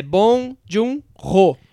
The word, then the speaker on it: Bong Joon-ho: e eu aprendi a pronúncia. É Bong Joon-ho.